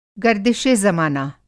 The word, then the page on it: गर्दिशे-ज़माना: گردش زمانہ; Hindi